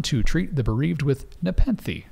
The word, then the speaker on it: Nepenthe: Nepenthe. – I would say Nepenthe.